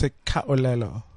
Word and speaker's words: Sekhaolelo: My surname is Sekwelelo.